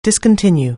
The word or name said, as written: discontinue